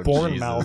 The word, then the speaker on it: Bournemouth: It's spelled like Jason Bourne, but it's Bournemouth.